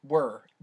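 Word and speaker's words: Wuhr: How do you pronounce it? – Wur. – I always say Wooher. – Yeah, it's W-U-H-R.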